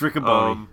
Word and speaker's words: Riccoboni: Say it right. It's Riccoboni. It's Riccoboni.